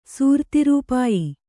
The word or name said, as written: ಸೂರ್ತಿರೂಪಾಯಿ